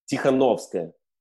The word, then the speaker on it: Tikhanovskaya: Tikhanovskaya.